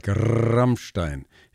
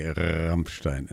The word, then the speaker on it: Rammstein: It's a band called Rammstein, and they say in southern Germany they roll the R like Rammstein. In northern Germany they say Rammstein, and the Americans say Rammstein.